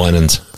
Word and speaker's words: Winans: Did I say that right? – Winans. – Winans. – Yes. – Not Winans. – Oh, it's Winans.